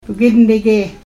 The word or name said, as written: bgidnige